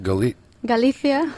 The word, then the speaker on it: Galicia: Galicia. – Galicia. – It's not a lisp. It's just the way we talk.